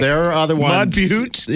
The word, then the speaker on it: Butte: I don't know if they have a mayor, but it's Mud Butt, South Dakota. Shut up. yeah. Mud Butte. I was going to say. I pronounce it butt. B U T T E.